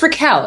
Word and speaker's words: Raquel: Maybe people have pronounced my name in so many interesting ways, like so interesting. Like, it's Raquel. It's not that hard. But I'll get Rachel, which, okay, fine. I'll allow.